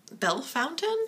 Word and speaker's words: Bellefontaine: Yeah, it's spelled like it should be Bellefontaine. – Yeah. – But I talked to my boyfriend, who's an Ohio native, and he's been to Bell Fountain. And that's what they call it.